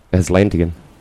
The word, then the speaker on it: Atlantean: Atlantean.